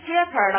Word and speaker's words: jeopardy: 动词呢，这里边哈，其实就它呢，这个单词呢，jeopardy 的哈，这单词是个动词，你看它发音 jeopardy 的哈，好像是那个姐姐 jeopardy 的哈，怕的，哈，姐姐怕什么？